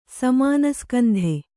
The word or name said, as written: ಸಮಾನಸ್ಕಂಧ